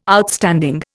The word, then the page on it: Outstanding: Indian